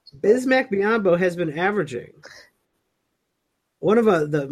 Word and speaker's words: Biyombo: I love it when they put the huge emphasis on the bye. Like, if you listen to a game and they're like, Bismack Biyombo.